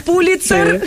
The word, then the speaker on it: Pulitzer: Pronuncias muy bien Pulitzer. – Pulitzer.